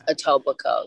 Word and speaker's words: Etobicoke: In that documentary, they famously call it like Etobicoke. they're saying it wrong, so it just reminded me of that. But it's Etobicoke, yeah.